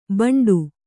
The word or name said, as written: ಬಂಡು